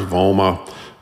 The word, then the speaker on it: Vomma: There's also, there's one called, I think it's pronounced VOMA, V-O-M-M-A.